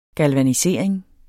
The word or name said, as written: galvanisering